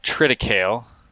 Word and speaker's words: triticale: Some people have said triticale. – Yeah. – Cereal grains course at Purdue. It was called triticale. So that's what I roll with.